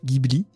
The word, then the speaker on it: Ghibli: Si en italien le fameux vent se prononce Ghibli, aucun des fondateurs du studio ne maîtrisait la langue de Marco Materazzi au moment de choisir le nom de leur boîte. Voilà pourquoi au Japon, on préfère une autre prononciation différente de celle rencontrée en italien et qui donne quelque chose comme Jiburi.